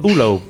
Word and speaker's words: Ullo: Ullo is, Ullo.